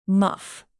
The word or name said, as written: muff